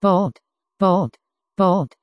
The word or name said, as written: bolt